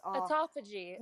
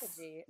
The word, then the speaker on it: autophagy: It's autophagy. – Yes. Autophagy.